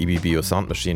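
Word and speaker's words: Ibibio Sound Machine: Nicht, dass ich EBBO Sound Machine das erste Mal richtig ausgesprochen habe, wobei das könnte auch eine Premiere gewesen sein, sondern dass wir diesen Song gespielt haben, Love.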